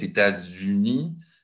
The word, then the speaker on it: États-Unis: Pour nous, la prononciation normale, c'est de faire la liaison. Et la liaison avec le S, États-Unis.